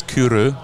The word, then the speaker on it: Kyrö: So how do you pronounce this distillery? – It's Kyrö Distillery Company. – Kyrö. – Well, I'm fine with Kyrö. If you say Kyrö, we're good.